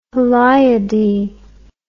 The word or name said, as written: heliadae